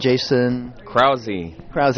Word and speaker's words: Krause: Jason... – Krause. – I never know how to say his name. – It's Jason Krause, K-R-A-U-S-E.